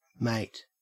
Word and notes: mate: Australian English

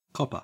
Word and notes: copper: Australian English

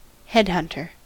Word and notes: headhunter: American English

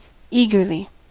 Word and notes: eagerly: American English

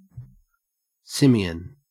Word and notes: simian: Australian English

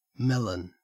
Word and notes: melon: Australian English

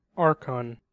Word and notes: archon: American English

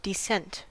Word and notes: descent: American English

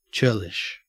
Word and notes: churlish: Australian English

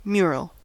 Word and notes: mural: American English

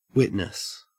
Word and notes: witness: Australian English

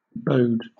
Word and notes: bode: British English

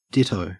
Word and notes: ditto: Australian English

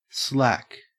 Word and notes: slack: Australian English

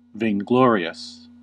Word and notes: vainglorious: American English

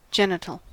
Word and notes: genital: American English

